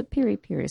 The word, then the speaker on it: piri piri: What is a piri piri sauce? Did I even pronounce that correctly? – You did. – Okay. – You did. – What? – One of very few that say it right.